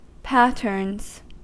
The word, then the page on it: patterns: American English